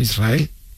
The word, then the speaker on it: Israel: Israel, ¿no? – Y la S con la R es difícil de pronunciar.